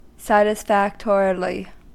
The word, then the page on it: satisfactorily: American English